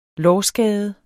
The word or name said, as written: lårskade